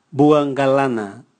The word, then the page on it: bua ngalana: Raga